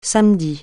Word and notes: samedi: francais

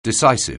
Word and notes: decisive: anglais

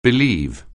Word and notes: believe: anglais

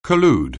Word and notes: collude: anglais

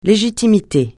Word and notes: légitimité: francais

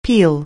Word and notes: peel: anglais

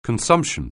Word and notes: consumption: anglais

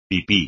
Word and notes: pipí: espagnol

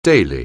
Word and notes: daily: anglais

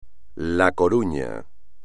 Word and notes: La Coruña: espagnol

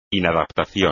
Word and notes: inadaptación: espagnol

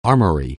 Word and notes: armoury: anglais

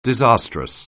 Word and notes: disastrous: anglais

